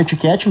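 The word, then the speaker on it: Ticchetti: Então, o Thiago Luiz Tiquete, me corrija se eu estiver errado na pronúncia do teu nome, Thiago. Thiago Luiz Tiquete está na linha com a gente. É Tiquete mesmo? – Boa tarde, etiquete mesmo, é italianíssimo, italianíssimo.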